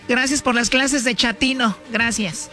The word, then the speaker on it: chatino: Chapino. – ¿Chapino? – No, chatino. – Es como chato, ¿verdad? Chatino. – Sí, asunero como chato, chatino.